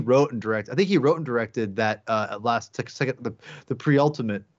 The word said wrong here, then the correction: penultimate: or is it penultimate? How do you say that word? – Penultimate.